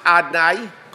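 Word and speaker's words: Adonai: It's written avaya. It's pronounced Adnai.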